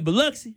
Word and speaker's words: Biloxi: That's how you say it, Biloxi? – Yeah.